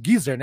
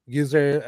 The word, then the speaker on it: Geezer: É, outra música uh, que Deezer, uh, ou Geezer, né, a patrulha da, da pronúncia aí, Geezer uh,